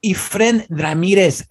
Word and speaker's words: Efren Ramirez: Why did I say it like that? – Efren, híjole, pan. Hijo, you're so... – What the fuck? – Eres tan Los Angeles, tú. Es Efren Ramirez.